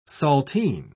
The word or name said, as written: saltine